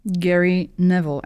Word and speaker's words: Gary Neville: Gary Neville asked this question. Is that how you say his name? – Yeah.